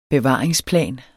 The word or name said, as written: bevaring